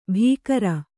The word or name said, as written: ಭೀಕರ